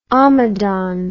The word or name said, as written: omadhaun